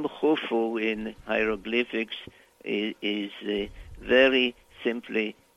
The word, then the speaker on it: Khufu: and then there is a symbol which is a circle with crossed lines that supposedly represent a sieve that is pronounced. So it's U F U Khufu, which we call Cheops in English.